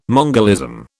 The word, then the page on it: mongolism: British English